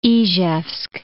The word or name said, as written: Izhevsk